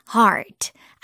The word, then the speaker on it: heart: Green heart. 아, 녹색은 green. 우리 배웠죠? 여기다가 하트 모양 heart. Heart. 이렇게 R 발음을 넣어서 하트 이렇게 하지 마시고요. R 발음 넣어서 heart. Heart. 이렇게 발음하면 좋겠죠? Green heart.